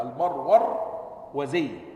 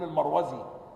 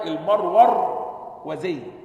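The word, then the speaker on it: المروروذي: يعني تنطق هكذا المرورزي، اضبطها كده، لأن دايما بيكون المروزي أو المروزي، لكن هنا المرور وزي، تمام؟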